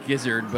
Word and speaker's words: gizzard: The izzard. Get that. It's about going to be at $161. – I believe it's pronounced gizzard, but I get where you're going.